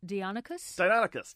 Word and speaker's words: Deinonychus: My favorite dinosaur as a kid, Marty writes, was, I'm going to mispronounce this, I think, was Deinonychus? – Deinonychus, yes.